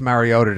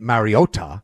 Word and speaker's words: Mariota: Like you said, number 1, you can hold down even after last night's, oh God, I've never wanted Marcus Mariota to complete, excuse me, Mariota.